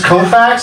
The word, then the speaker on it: Koufax: There's Koufax, is that pronouncing it right? – Yeah.